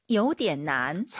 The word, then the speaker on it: 有点难: 有点难。 – 삼성이성. 点난. 이게 포인트예요. 요点 올라가면 안 돼요.